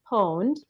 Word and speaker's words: pwned: It's Have I Been, and it'd be pronounced Pwned, but it's from all you video gamers out there know this. My husband knew this right away. So it's spelled P-W-N-E-D, and I'm sure Aaron will put it in the notes.